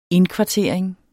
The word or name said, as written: indkvartering